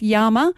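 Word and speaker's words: Yaama: So it's Yama. If you want to spell it, it's Y-A-A-M-A.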